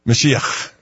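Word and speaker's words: Mashiach (משיח): Wait, how do you say Mashiach? – Mashiach. – Okay, good. I just wanted to know.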